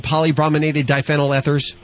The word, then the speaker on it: polybrominated diphenyl ethers: Am I anywhere near being correct in pronouncing polybrominated diphenyl ethers? – Yes.